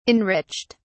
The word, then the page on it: enriched: British English